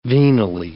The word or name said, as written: venally